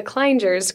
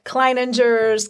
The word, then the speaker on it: Kleingers: I think we've had Kleiningers, Klingers, Klingers, you name it. It doesn't look like it's that hard to pronounce, but it is the Kleingers Group.